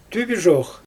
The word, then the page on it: tybyʒox: Xyzyl